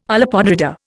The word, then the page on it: olla podrida: Indian